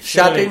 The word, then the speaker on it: Shadding: The page we've landed on is David Shading? Or Shadding? – Shadding. It's a double Oh, yeah, there's no E.